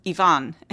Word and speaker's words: Ivan: Before we go on, because I realize that I'm already doing this, I would like the listeners to understand that the Russian pronunciation of Ivan Grozny's name is Ivan. And so in English, we say Ivan the Terrible, and it's so ingrained that I say Ivan the Terrible also.